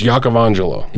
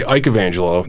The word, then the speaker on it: Iacovangelo: Okay, so technically, in Italian, it's Iacovangelo. – Iacovangelo. – Okay, but everybody says, a lot of people say Iacovangelo.